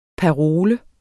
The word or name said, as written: parole